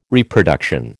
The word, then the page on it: Reproduction: American English